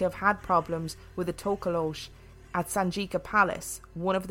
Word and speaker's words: Tokoloshe: Just before we go any further, so I looked this up, and Tokoloshe is just the most commonly used pronunciation, but there are lots of other pronunciations of Tokoloshe.